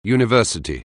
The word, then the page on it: university: anglais